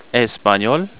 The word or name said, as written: español